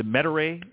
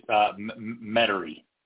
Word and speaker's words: Metairie: You've made a change, and you're now the head golf professional at it's the Metairie. Is that pronounced correctly? Country Club? – Just Metairie. – Metairie. Sorry. – So you don't really say air, just Metairie.